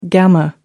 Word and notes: gamma: American English

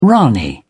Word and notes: ranee: British English

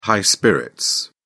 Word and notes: high spirits: British English

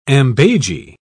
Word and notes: ambages: British English

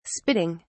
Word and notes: spitting: British English

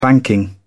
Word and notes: banking: British English

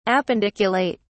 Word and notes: appendiculate: British English

